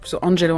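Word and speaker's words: Angelou: Et c'est là qu'elle prend ce patronyme Angelo, qu'on prononce Angelo en anglais.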